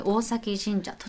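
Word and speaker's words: 大前神社: 大きいに前と書いて、うんうん、大前と書いて大崎神社。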